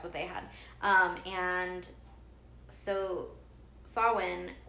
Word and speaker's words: Samhain: And okay, I used to think that it was pronounced Samhain because that's how it's spelled. But apparently, just like with a few other Irish words that are not spelled the way they sound at all, Saoirse Ronan, it's pronounced Samhain, like Samhain, or Samhain. Like, it depends on how you, I don't know, whatever, it depends on your accent, I guess.